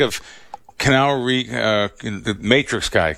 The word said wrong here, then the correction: Keanu: Am I saying his name right? Canal Reeves? – Keanu Reeves.